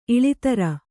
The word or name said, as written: ಇಳೆದೇರ